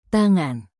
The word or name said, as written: tangan